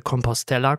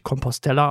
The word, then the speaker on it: Compostela: Ich habe es auch extra nachgeguckt, es wird wirklich Compostela ausgesprochen. Irgendwie begegnet einem häufiger auch, dass es Compostela ausgesprochen wird, was aber, glaube ich, nach meinen sehr einfachen spanischen Kenntnissen gar nicht so sein könnte, weil sonst müsste es ja, glaube ich, mit 2L geschrieben werden, wird aber mit einem L geschrieben.